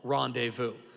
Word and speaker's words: rendezvous: But what we found out quickly is that nobody knows how to spell rendezvous. There's a silent Z. It's rendezvous.